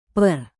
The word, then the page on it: per: indonesian